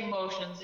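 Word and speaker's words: Moceans: you can find us on our website at Motions. Now I say Motions. It's spelled funny. It is Oceans with an M in front of it.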